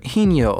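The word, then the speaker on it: Higinio: And the username was Hij Ochoa, which could be short for Hinyo Ochoa, which has a silent G in it.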